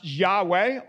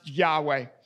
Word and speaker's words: Yahweh: Best that we can tell, it's pronounced Yahweh. Often in our translations, it looks like capital L, capital O, capital R, capital D. This often stands in for the personal name of God. And as best as we can tell, in Hebrew, Hebrew from right to left, that is most likely pronounced Yahweh.